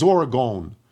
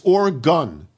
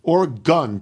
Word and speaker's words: Oregon: That said, I must write in to let you know that you frequently mispronounce Oregon as Oregon. It's Oregon. Oregon.